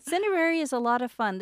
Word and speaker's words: Cineraria: Cineraria? – Yeah.